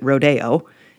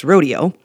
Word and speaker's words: rodeo: Because somehow culture decided to pronounce it rodeo, even though that's not correct. It's rodeo.